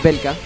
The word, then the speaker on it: Belka: usando el nombre del antiguo rey del Imperio Valka, Belka, bien la corrección. Encima lo escribí bien, lo dije mal.